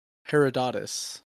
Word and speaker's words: Herodotus: So much that the Greek historian, Herodos, please tag me in here. You pronounce Greek things so much easier than I do. The Greek historian, Herodotus.